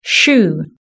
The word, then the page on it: shoe: British English